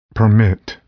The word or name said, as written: permit